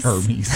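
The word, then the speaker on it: Hermès: What about, let's go to another luxury brand, H-E-R-M-E-S. Hermes. – Hermes. – I can't afford them. – It's Hermes. A-I-R. – Hermes. – That's how you should pronounce it.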